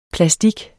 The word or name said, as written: PLASTIKKEN